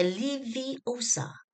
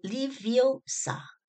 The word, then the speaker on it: Leviosa: 莱恩· 韦斯莱的羽毛飞不起，赫敏·格兰杰郑重其事地纠正了他的发音，就是你念错了，是 Leviosa 不是 Leviosa。